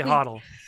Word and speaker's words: HODL: No, what is HODL's fine. HODL's fine. – You're saying it right.